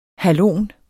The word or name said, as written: halon